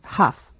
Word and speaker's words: half: Entonces diríamos it's 12:30, se pronuncia sin la L, half.